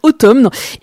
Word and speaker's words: Autumn: C'est assez original de pas sortir son album un vendredi, mais en tout cas est arrivé ce jour-là Autumn, qui se prononce en fait Autumn,